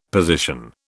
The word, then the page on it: position: British English